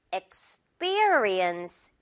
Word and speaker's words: experience: 일단 앞부분 X로 시작을 하고요. X 다음에 P라고 생각하지 말고요. B, experience.